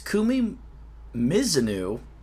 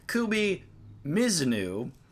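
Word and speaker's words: Kumi Mizuno: Nate brings it to my attention that we've been saying Kumi Mizunu's name wrong for 5 years. We've been saying Mizunu for 5 years. It's Mizunu.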